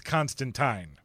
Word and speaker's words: Constantine: Or technically, if you live in Great Britain, they will tell you, you're saying it wrong. It is Constantine.